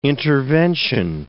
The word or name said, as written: intervention